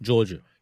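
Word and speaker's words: Jorja: Georgia. – Georgia? Yeah? – Yeah. – It's a weird name. Georgia. That's not a weird name. It's just the way to way it's spelled. It's fucking me up.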